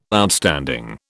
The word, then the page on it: Outstanding: British English